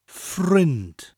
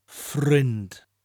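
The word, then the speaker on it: ffrind: Friend on its own is frind. Frind. Which rhymes with sinned. Oh, he's sinned. He's done something terrible. Sinned rhymes with the word that we are going to say, frind. Frind. So it's spelt like the English word friend, without the E, and is also pronounced in a similar way. Friend, frind. Friends, the plural, you just add I-A-U at the end to give us that sound E-I that we've used with other plural words.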